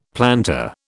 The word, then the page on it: plantar: British English